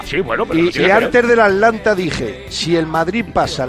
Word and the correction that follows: Atalanta: la eliminación, Atlanta, la eliminatoria del Atalanta, lo he dicho bien hoy. – Ahora sí.